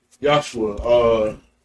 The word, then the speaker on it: Yahshua: There's no J in the Hebrew language. – Nope. – Okay, so they say Yeshua. – Yeshua. Yahshua. Yahshua is the proper pronunciation.